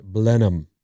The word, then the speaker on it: Blenheim: And 408 says, it's Blenheim is pronounced Blenheim.